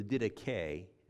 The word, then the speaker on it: Didache: And it's called the Didache or the Didache. That's how it's pronounced.